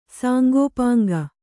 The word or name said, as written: ಸಾಂಗೋಪಾಂಗ